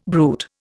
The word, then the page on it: bruit: Indian